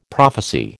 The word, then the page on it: prophecy: American English